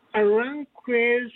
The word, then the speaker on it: Aranjuez: I can only, it's Aranjuez, I believe, Mon Amour. – Oh, yeah, we have that. – Pronouncing that correctly. – That's exactly it.